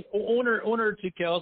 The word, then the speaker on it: Onur Tukel: It's like Onur, it's like corner without the C, or Onur, like, my southern friends. For years, they call me Onur, and then my friends north of the Mason-Dixon line would say Onur. And then my Greek friends in Turkish, people who know the actual Turkish pronunciation would say Onur. Onur is how they would pronounce it.